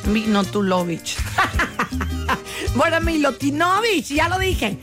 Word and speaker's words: Milutinovic: Se pronuncia Milutinovic.